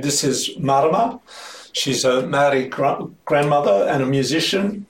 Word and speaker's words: Maori: No, but Maori. Maori. – Maori. – Maori. – Oh. Maori. – Maori. Thank you. Maori. That's good. Marama is.